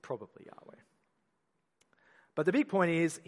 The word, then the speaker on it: YHWH: And you get the four letters, W-H-Y-W-H. Sorry, Y-H-W-H. That's God's proper name in the Hebrew sort of transliterated word. It's God's proper name, and it's probably pronounced Yahweh, but we can't be sure, and that's because those vowel points came in later, and the Jews were very